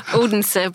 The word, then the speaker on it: Odense: I presume as you lay on your bed in Odense, am I saying that correctly? – Oh, Odense, but anyway. – Odense.